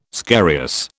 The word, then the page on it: scarious: British English